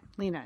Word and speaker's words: Lena: And then apparently HBO's Girls has inspired some baby names, and the names on that show are Shoshana, Marnie, Zosha, and Lena, or Lena. Lena is how she pronounces it.